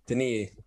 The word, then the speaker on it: Denis: I think all that goes I'm sorry if I say his name wrong, Denis Villeneuve or whoever, the guy who directed Denis. – It. Denis, good job to him.